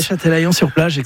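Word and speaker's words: Châtelaillon-Plage: Alors, vous n'avez pas y resté très longtemps non plus, vous revenez en France et vous allez, je ne voudrais pas l'écorcher, à Châtelaillon-sur-Plage, c'est comme ça qu'on le prononce. – Oui, Châtelaillon-sur-Plage, exactement, à côté de La Rochelle.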